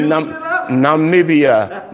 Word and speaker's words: Namibia: Namibia. – Yep, that's the one. – Namibia. – Amen. – That's it. I looked it up. Namibia.